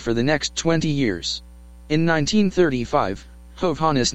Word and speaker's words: Hovhannes: However, Hovhannes' daughter Jean Nandi states in her book Unconventional Wisdom that my father's name at the time of my birth was Hovhannes, pronounced with accent on the first syllable.